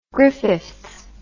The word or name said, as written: Griffiths